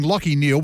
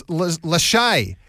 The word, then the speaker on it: Lachie: And went viral for pronouncing Lachie Neal, one of the best-known players in the AFL, as Lachey. Because to be fair, Lachie is spelt L-A-C-H-I-E in this case. So I can kind of see where he got it from, but however.